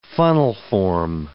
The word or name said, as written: funnelform